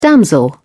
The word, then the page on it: damsel: British English